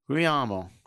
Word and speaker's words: Guglielmo: I'm going to bet any amount of money you want that G is silent. – Guglielmo? – No, the second G. – Oh, Guglielmo. – Guglielmo.